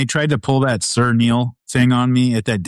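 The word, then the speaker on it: Niall: Two of my favorite people, Neil, it's pronounced Neil.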